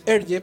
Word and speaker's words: Hergé: Nació en Bélgica el 22 de mayo de 1907 y fue obviamente un eh, historietista belga más conocido con el seudónimo artístico de Hergé, porque esa era la pronunciación en francés de sus iniciales invertidas, RG, de Remy y Georges.